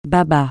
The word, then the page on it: baba: francais